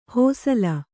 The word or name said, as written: ಹಸಲು